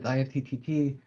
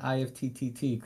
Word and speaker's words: IFTTT: So I'm very glad to know it's pronounced IFT, not IFTTT, correct? – We don't get too mad.